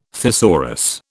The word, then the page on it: thesaurus: British English